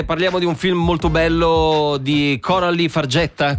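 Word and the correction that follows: Fargeat: com'è che si pronuncia qui? – Farjà? Sì, Coralie Farjà, The Substance, in onda sulla 1 venerdì alle 23:30.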